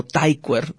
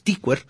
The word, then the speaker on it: Tykwer: Tom Tykwer, y sí, se pronuncia Tykwer, lo investigué porque es un apellido muy raro. La mayoría de la gente que conozco lo pronuncia como Tykwer, pero es Tykwer.